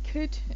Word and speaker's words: could: There you do need to pronounce the L, but with words like could and should and would, you don't, because it's a silent L.